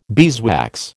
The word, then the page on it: beeswax: American English